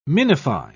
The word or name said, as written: minify